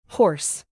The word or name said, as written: horse